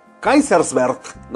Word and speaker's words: Kaiserswerth: Kaiserswerth. De Kaiserswerth. Allá, no, pues es que no, ¿cómo se pronunciará? Es alemán. Kaiserswerth, ¿no? Sí, sí, sí, o sea, suelita de Batman.